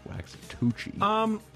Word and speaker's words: Waxahachie: Waxahachie? – I said it right. – You did say it right. – Waxahachie.